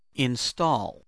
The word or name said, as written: install